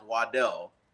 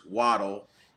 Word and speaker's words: Waddle: His original last name is pronounced Waddell, but he prefers Waddle.